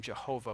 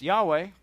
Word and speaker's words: Yahweh: But if you pronounce it incorrectly, you say the name Jehovah, which is just wrong. It's just incorrect on every level. So it's Yahweh, but the Hebrews didn't say Yahweh, right?